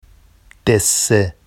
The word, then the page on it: desse: Vafsi